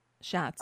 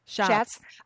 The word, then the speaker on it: Schatz: Schatz. – I'm sorry? – Schatz.